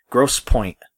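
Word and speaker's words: Grosse Pointe: Gross Point, Michigan but it's spelled it's G R O S S E P O I N T E.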